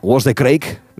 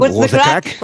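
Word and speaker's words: What's the craic: Con lo cual ahí podremos, Sharon, decirte a ti y al resto de componentes de The Corrs, What's the crack? Creo que lo he dicho bien. – What's the crack? – Eso. What's the crack? Es como el how are you, pero en irlandés, ¿vale? – What's the crack?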